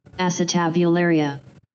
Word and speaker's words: Acetabularia: Acetabularia. – As you can see, I nailed it. The unicellular acetabularia is a control process that every multicellular life form is too.